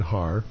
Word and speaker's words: Haar: Our landlord and landlady, John and Marion Haar, rhymes with bar, lived downstairs.